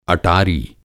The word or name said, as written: अटारी